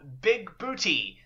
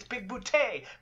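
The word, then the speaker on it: Bigbooté: And it's at this point which John Lithgow says Big Booty and pronounces it Big Booty one too many times, and John Big Boutte loses it. And he's like, it's Big Boutte, Big Boutte.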